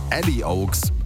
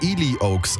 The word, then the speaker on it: Ely Oaks: Und der österreichische DJ und Musikproduzent Ali Oaks, also dachte ich bislang. Er hat jetzt im Interview klargestellt, wird anders ausgesprochen, wird Ely Oaks ausgesprochen.